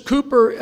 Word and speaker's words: Cowper: And this Cooper is spelled C-O-W-P-E-R.